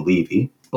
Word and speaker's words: Levi: It looks like it's spelled L-E-V-I. It could be Levi, but I listened to an interview with him, and they called him Levy.